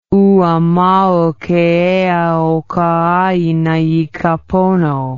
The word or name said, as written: Ua mau ke ea o ka aina i ka pono